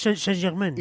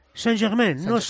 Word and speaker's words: Saint-Germain: Primero, intenta no pronunciar la S. Paris. Paris Saint-Germain. – ¿Y la S del Saint tampoco? – Saint-Germain, Saint-Germain.